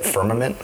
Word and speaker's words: Firmament: Firmament? – Yeah.